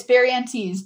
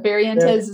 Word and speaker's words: Barrientez: It's Barrientez, but Barrientez is fine as well.